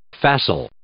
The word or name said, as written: facile